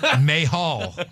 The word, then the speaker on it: Mahal: Like, he just went up to Jinder and goes, say your name so I can get it right. And Jinder's like, oh, thank you, finally. Mahal. – Well, that's how you really pronounce it.